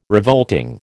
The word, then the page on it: revolting: American English